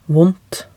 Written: vondt

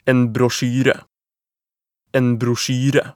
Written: en brosjyre

